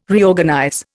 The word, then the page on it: Reorganize: Indian